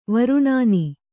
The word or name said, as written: ವರ್ಣನೆ